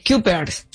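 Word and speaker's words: Cuypers: A ver, Kuipers, así se escribe, C-U-Y. – Kuipers.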